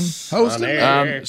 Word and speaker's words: Hostin: What is it? Hostin? Is I say your name? Yeah. Sonny Hostin. – Sonny. – Or is it Hostin? Hostin. – Hostin.